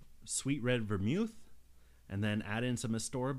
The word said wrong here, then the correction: Angostura: bitters. Angostura bitters. I am sorry. I mispronounce that thing so often.